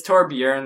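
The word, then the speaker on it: Torbjörn: Torbjorn? – Yeah. – Did I say it? – I heard it. – Apparently, it's Torbjorn. The O is pronounced as a U in Switzerland.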